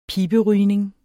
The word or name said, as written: piberygning